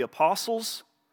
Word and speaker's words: apostle: Together, we are his house, built on the foundation of the apostles. Silent, there's a silent T in apostle, if you didn't know that.